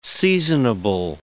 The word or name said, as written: seasonable